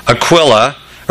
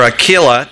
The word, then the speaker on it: Aquila: After these things, Paul departed from Athens and went to Corinth, and he found a certain Jew named Aquila, or Aquila, depending on pronunciation, born in Pontus, who had recently come from Italy with his wife Priscilla.